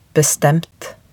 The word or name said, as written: bestemt